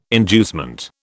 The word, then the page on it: inducement: British English